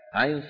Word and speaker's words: عين: جاء بفاتحتي سورة مريم وسورة الشورى، كاف ها يا عين صاد حاميم عين سين قاف. نعم. ينطق على ثلاثة أحرف أوسطها حرف لين مو حرف مد، لأنه ياء ساكنة مفتوح ما قبلها، ويمد بمقدار أربعة أو ستة.